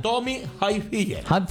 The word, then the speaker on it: Tommy Hilfiger: Tony Hilfiger. – ¿Cómo se pronuncia? – Tommy Hilfiger.